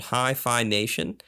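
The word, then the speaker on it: HiPhi Nation: I am listening to a podcast called Hi-Fi Nation. It's pronounced, or it's spelled H-I-P-H-I Nation.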